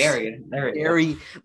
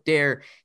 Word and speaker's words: Daire: He's Dairy, like milk. God. Apparently, he lives on a farm. But Dare, he pointed out D-A-I-R-E.